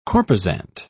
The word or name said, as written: corposant